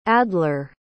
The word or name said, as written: adlar